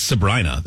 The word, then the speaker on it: Sabrina: I think it's Sabrina, though, because I remember reading... somebody said that the pop singer rhymes with vagina. Sabrina.